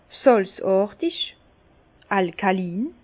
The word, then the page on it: sàlzàrtig, alkalin: Bas-Rhin; Herrlisheim